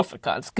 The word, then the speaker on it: Afrikaans: Afrikaans. Good pronunciation there, Zaid. – Thank you. I've been working on my khs really hard just for this.